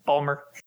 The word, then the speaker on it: Baltimore: Baltimore, as we call it. – Is that... How do I say it? – Balmer. – Balmer.